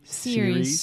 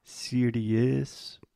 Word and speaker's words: series: A lot of our students have the tendency to say something like serious because we have the IE at the end, but the correct pronunciation is simply series.